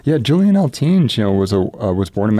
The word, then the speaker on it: Eltinge: Eltinge, yeah.